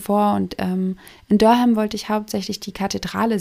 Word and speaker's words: Durham: Ich dachte erst, es heißt Durham, so wie der Schinkenham. Aber sie sprechen das eher kurz aus und betonen eher die erste Silbe und dann ist es sowas wie Durham.